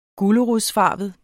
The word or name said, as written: gulerodsfarvet